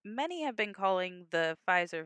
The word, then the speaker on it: Pfizer: Or the Pfizer. In Germany, they pronounce the P's.